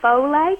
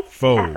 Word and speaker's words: Foles: and there's I understand that an Eagles quarterback, I think you pronounced the gentleman's name, so it's Nick, is it Foles or Foley? – Foles. – F-O-L-E-S. – Yep. You said Foles.